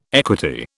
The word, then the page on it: equity: British English